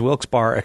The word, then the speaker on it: Wilkes-Barre: Is Wilkes-Barre acceptable, John? – It works for me.